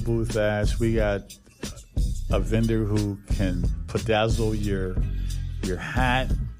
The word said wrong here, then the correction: Bedazzled: Bedazzled is the word, yes.